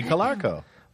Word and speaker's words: Calarco: How do you spell that last name? C-L-A-R. Clarko? – C-A-L-A-R-C-O. – Clarko. – Colarco. – Yeah.